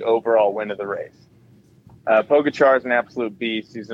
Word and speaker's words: Pogačar: Because it's not Pogačar, because he let us down first stage.